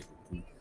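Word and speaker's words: Go (五): De por qué el 9 de mayo es el día de Goku. – El 5 del 9. El 5 se pronuncia Go en japonés y el 9 Q.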